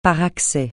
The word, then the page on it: par accès: francais